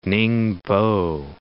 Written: Ningbo

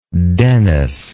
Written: Denis

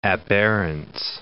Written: aberrance

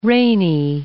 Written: rainy

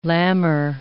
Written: lamber

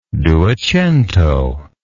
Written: duecentist

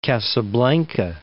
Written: Casablanca

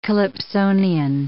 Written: calypsonian

